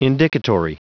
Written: indicatory

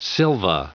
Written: silva

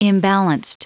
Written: imbalanced

